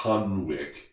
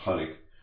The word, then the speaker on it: Hunwicke: You look up Hunnick, it looks to a lot of Americanized like it's Hunwick, Hunwicke. But it's pronounced Hunnick,